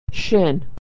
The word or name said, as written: shin